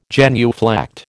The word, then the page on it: genuflect: American English